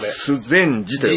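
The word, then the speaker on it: 修禅寺: 須禅寺って読むんですか、これ。 – 須禅寺と読むんです。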